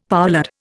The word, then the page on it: poulard: Indian